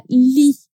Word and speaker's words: lits: Atenção, essa palavra lit, que é cama em francês. Camas, no plural, seria lit, com um S a mais que a gente não pronuncia.